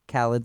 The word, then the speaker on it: Khalid: He's also known as just Khalid. Khalid, like DJ Khalid. – Yeah. – Try my best to keep the pronunciation authentic, but yeah.